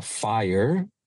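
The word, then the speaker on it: FHIR: Y en conectividad, muy interesante el cambio que se quiere llevar a cabo de HL7 a lo que se llama FIRE en inglés, y es pronunciado como fuego, pero escrito FIHR.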